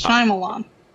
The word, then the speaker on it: Shyamalan: It's not hard to pronounce. It's three syllables, Shyamalan.